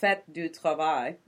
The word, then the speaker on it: Fête du Travail: It was the Fête du Travail.